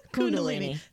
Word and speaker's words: Kundalini: So we are talking about, I'm going to say it wrong again, Kundalini awakening. Kundalini? – Kundalini. Sounds like a body part when you say it the other way.